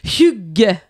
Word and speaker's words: hygge: Mais c'est parce que ce qu'il faut savoir, c'est que ça s'écrit H-Y-G-G-E, mais ça se prononce Hug.